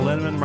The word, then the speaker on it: Lenneman: Val Lenneman, Rhymes with Cinnamon,